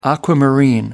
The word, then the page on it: aquamarine: American English